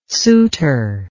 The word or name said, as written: suitor